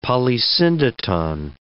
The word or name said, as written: polysyndeton